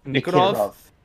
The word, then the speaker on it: Nikiforov: Nikiforov. I'm pretty sure is his name. I don't want to butcher that. – Yes, Nikiforov.